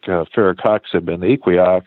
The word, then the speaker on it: Firocoxib: Firocoxib. – Firocoxib.